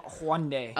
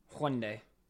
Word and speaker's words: Hyundai: And I don't know if it went anywhere, but that's what I heard, is that they're trying to make cars with Hyundai. However you want to say it. – Hyundai. – I don't even know how to say it, and I drive one. – Hyundai.